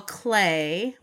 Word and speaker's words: Klee: We have Paul Klee, which is how you pronounce it, K-L-E-E, a Yiddish surname.